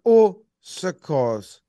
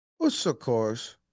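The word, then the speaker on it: Au Secours: I'm giving the Usikos award. – I need you to say it and maybe spell it for BBD. – Ready? Usikos. Usikos. Usikos. It's A-U space S-E-C-O-U-R-S. A-U space S-E-C-O-U-R-S.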